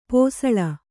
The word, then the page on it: ಪಸುಳೆ: pasuḷe